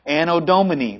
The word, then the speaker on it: Anno Domini: Anno Domini, very good.